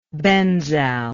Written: benzal

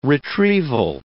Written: retrieval

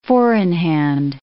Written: four-in-hand

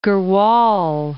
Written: Tehri Garhwal

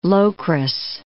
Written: Locris

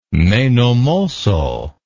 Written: menomosso

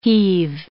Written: heave

